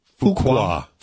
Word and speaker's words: Fuqua: Antoine Fuqua.